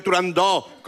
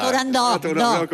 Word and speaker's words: Turandot: Yo también lo digo mal. Si es que son muchos años diciéndolo mal. En el mundo exótico en el que vive Turandot, con O abierta. – Turandot, con O abierta.